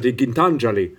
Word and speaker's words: Gitanjali: And an Indian woman came and she says, you know, it's actually pronounced Gitanjali. And I've always said Gitanjali, but it's said Gitanjali.